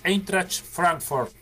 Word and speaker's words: Eintracht Frankfurt: Porque eh, se estaría confirmando en estos días eh, que sería traspasado al Eintracht Frankfurt.